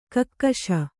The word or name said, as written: ಕಕ್ಕಶ